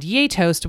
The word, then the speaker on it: gjetost: And yay toast, which is spelled G-J-E-T-O-S-T. And my good friend Victoria, our good friend Victoria, helped me remember it by saying, you have to remember it's like, yay toast. Like, we love toast.